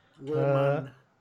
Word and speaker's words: Rohmann: Andrew Roman. R-O-H-R-Man, double N. – Andrew Rohan. – Roman. – Oh, God. Spell it again. – R-O-H-M-A-N-N. – M-A-N-N.